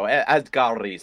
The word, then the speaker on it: Asghari: Asghari. – That's it. There we go. Asghari.